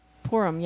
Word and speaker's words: Purim: Purim, yeah. – P-U-R-I-M, which is part of the... – As in pour me another glass of...